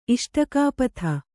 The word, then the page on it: ಇಷ್ಟಕಾಪಥ: kannada; iṣṭakāpatha